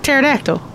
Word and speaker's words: pterodactyl: Or like Pterodactyl. – Like Pterodactyl, too. – There's just 1 P is silent at both of them.